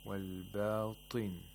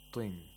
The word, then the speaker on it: والباطن: Notice how Minshawi pronounced the ta with a kasra. والباطن, طن, not طن. We don't give too much tafkhim whenever a ta has a kasra.